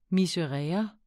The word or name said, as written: miserere